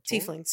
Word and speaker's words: Tieflings: I had that feeling when I think I'm going to pronounce the name wrong, but the horn people, the Tieflings. – Tieflings.